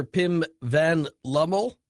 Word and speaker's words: Pim van Lommel: Dr. Pim Van Lommel, am I pronouncing all of those words correctly? Am I off to a good start? – Yes. – Okay. And Dr. Lommel, or is it Dr. Van Lommel? – It's Dr. Van Lommel.